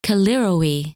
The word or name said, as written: Callirrhoe